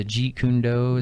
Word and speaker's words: Jeet Kune Do: But his style, he's a Jeet Kune Do.